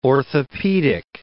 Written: orthopedic

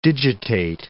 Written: digitate

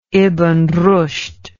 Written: ibn rushd

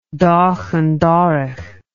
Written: doch an dorrach

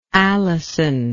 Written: Allison

